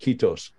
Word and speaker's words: Kiitos: Kiitos, I think. So. I don't know if I say that right, but that's, I think, Finnish for thank you. – Yes, it was absolutely correct.